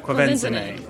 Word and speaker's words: Quvenzhané: Can anybody here say the name correctly? Anyone? – No, I don't even know who that is. – Is that right? – Quvenzhané. – Oh, very good.